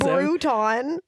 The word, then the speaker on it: Bruton: It's Bruton. – So. Like Crouton, I'm telling you. – I said that when we were watching. I was like, why is his name Crouton with a B? – Earl, but it's spelled with a URL.